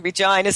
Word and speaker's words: Regina: I'm now back in Saskatchewan in Canada, in the city that rhymes with fun, Regina, Saskatchewan.